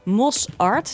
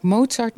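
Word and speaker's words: MOSart: Mos Art, zeg ik het goed? – Ja, dat klopt. Mensen zeggen vaak Mozart muziekschool, maar het is eigenlijk muziekonderwijs Zuidplas.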